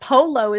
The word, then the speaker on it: polo: But polo is both open syllables. – Polo, right. Mm-hmm. – But because you're producing the O sound, it's actually a long, it's actually a diphthong. So it's O.